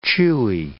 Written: chewy

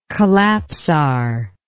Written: collapsar

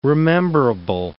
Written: rememberable

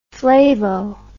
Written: flavo

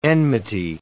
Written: enmity

